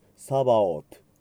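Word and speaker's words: Sabaoth: サンクトゥス、サンクトゥス、サンクトゥス、ドミヌス、デウス、サバオト。最後の TH が英語ではありませんので、サバオト、 H を発音しないと考えてください。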